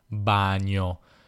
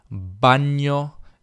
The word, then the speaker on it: bagno: pare questa è la pronuncia corretta, io dicevo invece e ho sempre detto bagno, bagno e bagno, bagno, bagno. Non so se sentite la differenza,